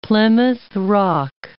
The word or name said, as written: Plymouth Rock